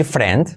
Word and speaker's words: friend: Y vale, y podemos decir friend, pero para hacerlo bien, hacemos la U después de la R. Friend, aunque cueste.